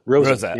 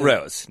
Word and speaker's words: Rose: Not Rose. Not Marco Rose. Rosa. – Yeah. You know, every single syllable must be pronounced in German. There are, you know, you can't just get away with these silent letters at the end of a word.